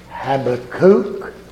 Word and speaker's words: Habakkuk: I'm going to read in a moment from the first chapter of the book called Habakkuk. If you prefer to be correct, you can call it Habakkuk. That's what I'm told is correct pronunciation.